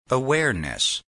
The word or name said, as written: awareness